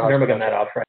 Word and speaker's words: Nurmagomedov: Nurmagomedov, right? – Yeah. Nurmagomedov. Great pronunciation, by the way.